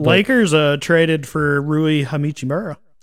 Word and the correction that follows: Hachimura: Hachimura. – I was adding an extra syllable there. Rui Hachimura.